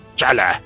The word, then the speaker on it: T'Challa: Según yo, se pronuncia Shala, porque la T era silenciosa, pero en el MCU y en muchas otras partes ya le dicen T'Challa, así, Chala.